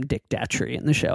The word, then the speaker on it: Datchery: There's a character in the show named Dick Dacquery. – Yeah. – No, I just said it wrong. Dick Datchery.